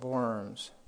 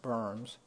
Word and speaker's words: Worms: So the emperor convened what was called the Diet of Worms, W-O-R-M-S. We would say worms, the Diet of Worms. It's actually pronounced berms.